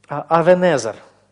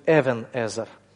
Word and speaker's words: Эвенезер: Авенезер – камень помощи. Хотя более точно вот в масоретском произношении это будет Эвенезер в масоретском произношении.